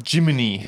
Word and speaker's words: Gemini: Is it Gemini? – It's Jiminy. – Jiminy Cricket? – Like Jiminy Cricket, yeah. In Japan, Gemini is pronounced Jiminy.